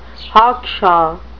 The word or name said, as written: hawkshaw